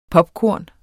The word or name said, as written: popkorn